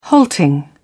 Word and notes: halting: British English

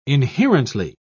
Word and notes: inherently: British English

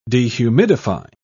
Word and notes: dehumidify: British English

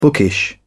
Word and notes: bookish: British English

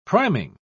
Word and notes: priming: British English

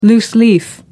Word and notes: loose leaf: American English

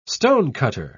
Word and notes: stonecutter: British English

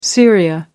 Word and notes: syria: American English

